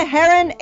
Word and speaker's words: Hryn: You can find the Polina Heron Show, which is a weird spelling. My last name is H-R-Y-N, which is weird.